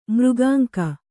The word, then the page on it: ಮೃಗಾಂಕ: mřgānka